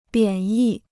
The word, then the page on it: 贬义: biǎn yì